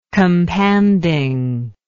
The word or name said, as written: companding